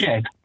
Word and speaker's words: Jed: It's Jed. – It's what? – Jed. – It's Jed. It's not even Jev. It's Jed with a D.